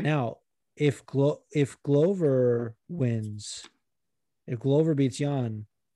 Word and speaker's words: Glover: Glover is the proper pronunciation.